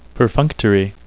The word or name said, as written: perfunctory